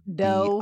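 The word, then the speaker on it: Do: So is it Doe as in like John Doe or like Deer Doe? – D-O, but it's pronounced Doe.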